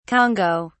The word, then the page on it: congo: British English